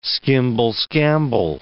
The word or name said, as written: skimble-skamble